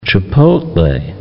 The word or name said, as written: chipotle